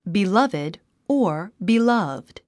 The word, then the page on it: beloved: bilʌ’vid; ビラビィド